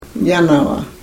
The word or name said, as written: yanava